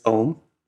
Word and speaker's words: Ogham: So the pronunciation is Ohm. It's not Ohm. Still, Ohm. Gosh.